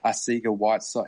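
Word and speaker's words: Arcega-Whiteside: Arcega Whiteside? Is that the pronunciation? – Yeah, depends if you want to put the accent on it. – Yeah. Arcega.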